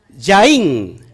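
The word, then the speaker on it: Yain (יין): Yud, yud, Nunsofit, Yud, yud, nun ¿Y cómo se, pronuncia vino? Yain. Yain es vino.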